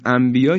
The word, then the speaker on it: انبیاء: یا انبیاء، انبیاء تو فارسی هم وقتی ما میگیم انبیاء، هیچ وقت نمیگیم انبیاء، ما میگیم انبیاء، یعنی میم رو تلفظ میکنیم.